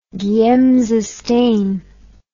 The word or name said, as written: giemsa stain